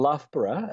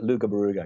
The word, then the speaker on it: Loughborough: We've got a place south of us called Loughborough, and a lot of folk from the States call it Lugabaruga because that's how it's spelled.